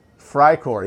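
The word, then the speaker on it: Freikorps: Fry Corps, yeah, F-R-E-I Corps. It sounds like fry, but that is the correct pronunciation. I'm not going to roll my R's or anything. Fry Corps, it's Fry Corps.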